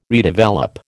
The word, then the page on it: redevelop: American English